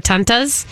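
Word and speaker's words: Tante: Is that how you say it? – Tanta. Yeah, okay. That's aunt. Like, if I say aunt honey, it's Tanta honey. – And Tanta is that... – That's aunt. That's German for aunt.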